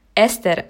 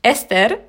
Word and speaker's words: Esther: Ähm, und da das hier, also ich wohne ja in Deutsch, ich lebe ja in Deutschland, ähm, da die meisten hier das eher nicht rollen können, ähm, habe ich gesagt, komm, ist gut, sagt einfach Esther. Aber normalerweise wird der Name Esther ausgesprochen. Moment, ich mach's nochmal, genau zuhören. Ähm, Esther, Esther, Esther, Esther.